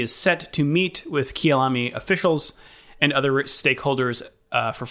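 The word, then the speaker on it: Kyalami: South Africa last hosted a Grand Prix at the, and I don't know how to pronounce this, Kialami track. – That's how I've always heard it.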